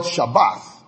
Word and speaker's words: Shabbath: In Genesis chapter 2, where you first read the verb Sabbath, is spelled Shabbath, S-H-A-B-B-A-T-H.